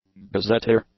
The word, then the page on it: gazetteer: english